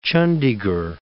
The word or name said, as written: Chandigarh